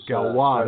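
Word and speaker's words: Gauloises: She started in the Raid Galois, which was Galois, that's how you say it.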